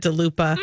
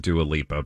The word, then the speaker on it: Dua Lipa: Oh, I love that DeLupa. – Crunchy. – I don't get that with a churro. – That's what Julia called Dua Lipa, by the way. We know how to say her name.